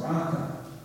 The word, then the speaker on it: raca: Well, it's a rough translation of this Aramaic word, which is pronounced raka.